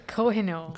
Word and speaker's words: Kohinoor: And one of the key points of that is a Cardinal diamond, which is, did I say that right? – I would say Kohinoor. – Kohinoor. – There we go. Kohinoor diamond. You kind of got it, yeah.